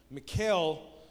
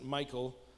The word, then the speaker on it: Michal: But Michal, and I think that's how I've heard people pronounce her name, Michal. I don't like to pronounce it Michael, because that's my name, and that's a man's name, clearly, and not a girl's name.